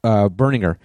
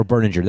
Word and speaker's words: Berninger: And just now, you know, is it Berninger or Berlinger? – Berninger. – Berninger was not our... – Or Berninger. That was the debate that we had with them, how to pronounce it. They said either way. They said you could pronounce it either way, which I thought was kind of a weird thing.